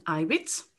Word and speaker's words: Ibitz: Uh, my name is Susan Ibitz. It's pronounced like on the uh, on the first letter.